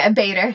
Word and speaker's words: Bader: Is it Bader, or I always say Bader. – Bader. – Bader. Ruth Bader Ginsburg.